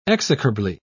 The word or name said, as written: execrably